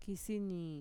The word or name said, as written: kisinii